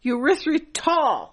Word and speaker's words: erythritol: And another thing to watch out for, erythritol. – And good job on pronouncing it. – I don't think I even pronounced it right. Erythritol. Erythritol. Erythritol. There we go. That's the one.